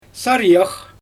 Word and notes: sarijax: Xyzyl

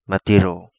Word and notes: Matiro: Kapingamarangi

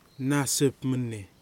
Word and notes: Nasipmeni: Kwamera

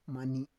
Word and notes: mani: Roon

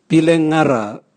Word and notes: bilengara: Raga

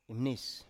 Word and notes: imnis: Biak

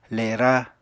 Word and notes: lera: Apatani

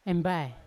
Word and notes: embai: Yawa

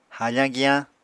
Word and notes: hafañ giyañ: Apatani

